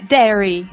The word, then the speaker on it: dairy: É, é dairy. – Dairy. Esse i é mudo. – Ah, sim, o, é, o i do meio da palavra. Sim, a gente fala dairy. – Que se escreve diary, né? – A gente escreve diary. – Diary. – Mas a gente pronuncia dairy.